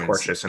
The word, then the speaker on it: tortious: Yeah, one of the words apparently I pronounced it wrong because I can't read. Shocker. Uh, it's tortious interference. Tortious interference.